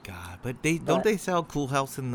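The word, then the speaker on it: Cool Haus: Yeah, but it's spelled HAUS. – Oh shit, house. – It's Cool House.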